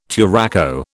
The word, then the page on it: turaco: British English